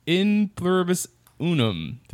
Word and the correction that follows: E Pluribus Unum: Did I say that right? – E Pluribus Unum.